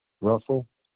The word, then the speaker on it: Russell: And Russell is spelled RUSSELL. – Correct.